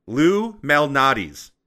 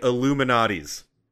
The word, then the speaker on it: Lou Malnati's: Y'all don't pronounce it correctly. Lou Malnati's. Everyone says it like they're saying Illuminati's. So fucking badly that's the pizza chain I searched for on my phone the first time someone said it to me.